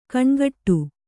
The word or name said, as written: ಘನಘಟ್ಟಿ